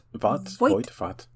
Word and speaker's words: Voight: Like John Void in Anaconda. Mm-hmm. Is that his name? Void. – John Voight, Voight. No wait, Voight. – Voight. – That's exactly how everybody in this family pronounces it. Voight.